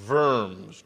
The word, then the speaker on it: Worms: It actually is the Council of Worms, because W's are not pronounced W's in German. Wittenberg, it's a double V. Worms.